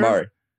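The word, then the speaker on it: Jamaree: Tell me if I'm saying his name right. Jamari Sawyer? – Yep, Jamari. Yep.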